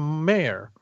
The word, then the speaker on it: Maher: It's written by Jed McKay with art by Perry Perez, colors by Frank Darmada, and letters by VC's Ariana Mayer. Amar, actually. You know, we actually, I decided unilaterally that we were going to pronounce it like Bill Maher.